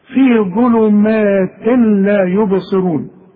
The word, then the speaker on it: ظلمات: شوف، في ظلمات تنوين وبعده لام، يبقى مش هنطق التنوين ده.